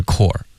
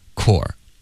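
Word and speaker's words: corps: 是因为它是一个法国的一个发音，就是 core，然后它是一个 it's French。所以就是他来到英文的时候呢，我们还是尽量用法语的发音发这个字，所以就发 core。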